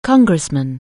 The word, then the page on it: congressman: anglais